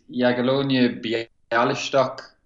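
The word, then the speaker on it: Jagiellonia Białystok: It's Jagiellonia Bialystok.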